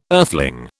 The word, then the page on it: earthling: British English